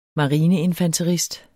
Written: marineinfanterist

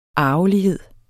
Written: arvelighed

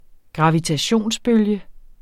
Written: gravitationsbølge

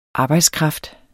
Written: arbejdskraft

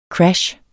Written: crash

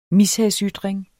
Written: mishagsytring

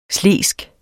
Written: slesk

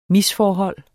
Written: MISFORHOLDET

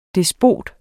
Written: despot

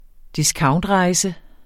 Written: discountrejse

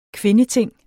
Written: kvindeting

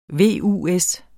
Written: VUS

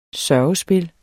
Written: sørgespil

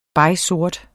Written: begsort

